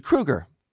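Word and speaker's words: Kroeger: They call him Kroger, because it does look like it would be pronounced Kroger. And he says, "No, I'm not the grocery store." And he spelled it out phonetically, Crew, C-R-E-W.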